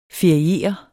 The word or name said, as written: feriere